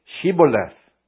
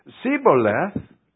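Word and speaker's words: Shibboleth: And if he said no, then they would say to him, "Then say Shiboleth." And if he would say Siboleth, for he could not pronounce it right, then they would take him and kill him at the fords of the Jordan.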